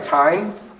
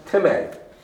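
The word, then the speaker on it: timē (τιμή): Well, you see the word, it looks like time, but it's actually pronounced timē, and it means respect, regard, a price, an honor, pay respect, perceived value, worth compensation and money.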